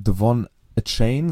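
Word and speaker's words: De'Von Achane: Ähm, Devon A Chain, so haben es zumindest die Kommentatoren äh, im Original ausgesprochen, hat 203 Yards vom Scrimmage mit 18 Carries, zwei Touchdowns.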